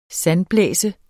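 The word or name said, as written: SANDBLÆSE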